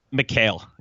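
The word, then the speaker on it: Mikal: Michael Bridges is the guy, or is it Michael or McCall? How do you say it? Or how have you heard said? – McHale. Yeah.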